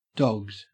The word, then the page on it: dogged: Australian English